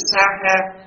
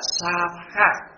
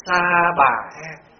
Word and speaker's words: Sa Ha: Rồi giờ nói cái chữ Sa Ha. Cái chỗ, cái nguyên âm của tiếng Phạn là Sa Ha. Khi mà đọc là chữ H á, theo Sa, chữ V đó, nó nhẹ đi, đọc là Sa Ha. Thì ngoài Bắc phiên âm là Sa Bà Ha. Bởi mình âm cho nó rõ, có chỗ âm là ta bà ha.